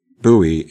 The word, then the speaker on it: buoy: Buoy and boy are both valid pronunciations.